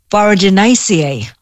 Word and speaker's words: Boraginaceae: Yes, and it's a herb in the flowering plant family, Boraginaceae. Did I say that right? – You did. You said that right.